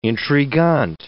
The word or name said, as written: intrigant